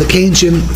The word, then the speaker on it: Keynesian: Who would represent the, am I saying it right, Keynesian? – Yeah, the Keynesian view, yeah.